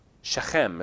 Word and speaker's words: Shechem: Shechem is how you'd pronounce it.